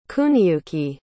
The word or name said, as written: Kuniyuki